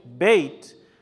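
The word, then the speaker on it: Bet: Whenever you have the dot in the B, it's Be. Aleph, Beit. But when the dagesh or the dot is out, the B is actually pronounced as a V. Aleph, Beit, Veit.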